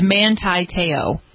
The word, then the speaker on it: Manti Te'o: He says that we're saying it wrong, which I fully admit that I might be, but it just so that he gives us the correct pronunciation, it's Manti Te'o.